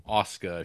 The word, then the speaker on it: Asuka: I think it's Ahsoka on Star Wars, but it's Asuka if you watch Neon Genesis.